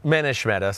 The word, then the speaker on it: Manish Mehta: I think it's Manish Mehta. – Manish Mehta. Sorry, you're right.